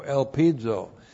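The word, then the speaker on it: elpizo (ἐλπίζω): looks like that in the Greek, E-L-P-I-Z, long O, elpizo.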